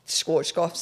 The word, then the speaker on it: Schwarzkopf: I used the Swarf. How do you say that? I think it's actually beard dye. That brand, Schwarzkopf. You know what? – Schwarzkopf.